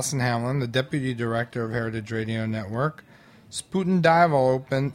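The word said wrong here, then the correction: Spuyten Duyvil: Spuiten Dival. So the U and the Y is pronounced like an I.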